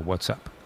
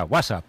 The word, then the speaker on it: WhatsApp: No, peor aún, con acento en la primera. WhatsApp, ¿no? Un WhatsApp. – WhatsApp.